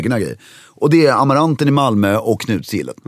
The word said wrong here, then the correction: Knutsgillet: Lite felbetoning där. Knutsgillet, ja, hur nu heter.